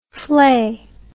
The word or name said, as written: vlei